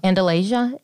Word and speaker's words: Andalasia: Andalasia, isn't that it? – Yeah.